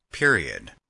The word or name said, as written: period